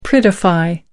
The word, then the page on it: prettify: American English